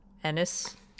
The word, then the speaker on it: Ennis: I keep mispronouncing the town. Ennis. It's like it's like Dennis. Take away the D.